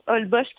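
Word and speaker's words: Holbox: Ça s'écrit Olbosch avec un X. Ça se prononce Olbosch, qui est une petite île qui est quand même très populaire.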